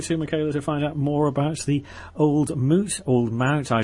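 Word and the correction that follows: Old Mout: The most mispronounced cider in the world. It's Old Moot Cider.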